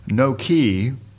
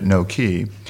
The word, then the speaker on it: Noke: It's called Nokee. Spelled N-O-K-E, one word. You might say Noke, but I guess they pronounce it Nokee.